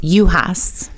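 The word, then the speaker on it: Juhas: How do I correctly pronounce your last name? – Yuhas. – So the J is silent. – It is. – The J is silent. Yuhas.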